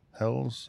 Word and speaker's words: Helles: I think it's Helles. That's been one of those words that I've been back and forth on the pronunciation, but I've listened to other people say it, and they all seem to be saying Helles. So I'm going with that.